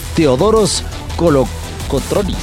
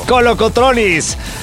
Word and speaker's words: Teodoros Kolokotronis: Gusto saludarte, acompañarte aquí desde el estadio Teodoros Kolokotronis. ¿Está bien? – Sí, perfectamente pronunciado. – Kolokotronis.